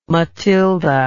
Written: Matilda

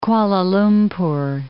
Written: Kuala Lumpur

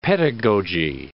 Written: pedagogy

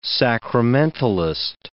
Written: sacramentalist

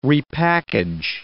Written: repackage

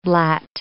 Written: blat

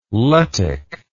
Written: Lettic

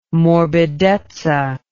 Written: morbidezza

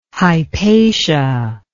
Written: Hypatia

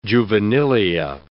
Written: juvenilia

